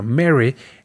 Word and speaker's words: Merry: Pero bueno, Merry, en inglés, aunque se escribe Merry con doble R, esa R que nosotros en español, como latinos, hacemos esa vibración de R. En inglés es una R completamente suave y la lengua va más para atrás, entonces decimos Merry.